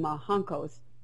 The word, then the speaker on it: roncos: They called them roncos, R-O-N. C-O. In Brazil, they pronounce the R as an H.